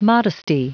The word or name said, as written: modesty